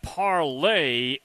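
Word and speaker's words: Parler: Now, pronounced parlay is said to be much like Twitter.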